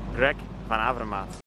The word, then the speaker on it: Van Avermaet: hvor øh, Greg så selv fik lov at udtale sit navn rigtigt. – Greg Van Avermaet. – Og her hvor vi jo konstaterer, at vi i Danmark udtaler navnet forkert, øh, vi vil bestræbe os på at gøre det bedre fremover, og rigtig få, få gang i vores øh, lyde nede i halsen.